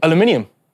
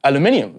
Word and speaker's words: aluminium: How do you say aluminum? – Aluminium. It's actually spelled differently. Aluminium is how it is.